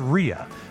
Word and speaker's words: Rea: That is actually pronounced Chris Rea. My apology to all the Reas.